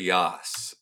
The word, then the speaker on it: Yass: So the man is Jeffrey Yass, Y-A-S-S. I believe it's pronounced like Yass Queen.